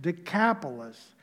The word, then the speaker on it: Decapolis: The area that they are going to is called the Decapolis or Decapolis. Deca for 10, polis, city or town. So then in English, we pronounce it Decapolis.